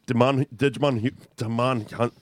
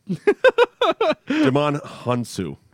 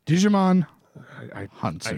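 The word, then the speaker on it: Djimon Hounsou: Digimon Hunt. Ah, fuck this. – I'm going to give you one more try at it. – No. Digimon Hunsu.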